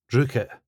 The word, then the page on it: drookit: スコットランド英語